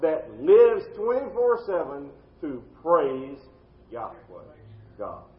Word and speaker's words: Yahweh: We believe, we don't know for sure, we believe that this name of God, the very name that God gave through Moses at the burning bush, is pronounced Yahweh, Y-A-H-W-E-H.